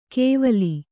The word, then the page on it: ಕೇವಲಿ: kēvali